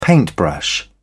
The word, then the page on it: paintbrush: British English